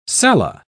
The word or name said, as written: cella